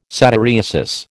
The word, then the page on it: satyriasis: American English